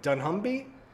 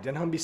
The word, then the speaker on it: dunnhumby: It's called the, and if I'm pronouncing it right, Dunhumby? D-U-N-N-H-U-M-B-Y, Dunhumby study.